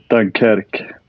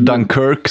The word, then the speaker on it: Dunkerque: På franska är det Dunkerque. – Vi andra säger väl Dunkirk snarare, va? Vi som är lite mer anglofona. – Precis. På engelska är det Dunkirk då.